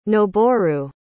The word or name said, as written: noboru